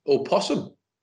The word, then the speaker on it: opossum: But during my research for that, I discovered, for what I think might be the first time in my life, does possum have a silent O before it? Or possum? – What? What? As in it's spelt with an O at the beginning? – Yeah. – Opossum.